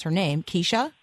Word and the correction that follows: Kesa: Kesa. – See, Kesa. There you go.